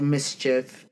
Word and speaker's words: MSCHF: So anyway, the shoes are made by a brand called Mischief, and that's spelled M-S-C-H-F.